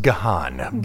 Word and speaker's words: Gahan: Dave Gahan is the lead. I've also heard it pronounced Gahan.